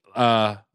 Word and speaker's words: lychee: How do you say lychee? Lychee? – Lychee.